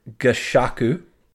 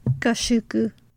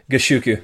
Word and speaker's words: Gashoku: All right, so in October of that year, AAA started their very own variety show titled AAA no Kizuna Gashoku. – Gashoku. – Gashoku? Oh, thank you. Gashoku.